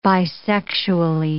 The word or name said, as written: bisexually